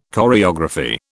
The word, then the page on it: choreography: British English